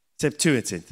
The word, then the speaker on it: Septuagint: They called this, this together of books, they called it the Septuagint not quite sure whether I pronounced it right. Septuagint, that's the one.